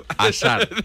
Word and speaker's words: Hazard: Es Hazard.